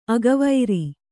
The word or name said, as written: ಅಗವೈರಿ